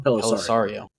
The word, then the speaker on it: Pelissero: That was reported by NFL insider Tom Pelissero. I said that right? – Tom Pelissero. – Oh, yeah, I was close.